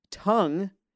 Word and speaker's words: Tunng: Tongue is spelled T-U-N-N-G, but it is pronounced like the muscle that's flopping around in your mouth.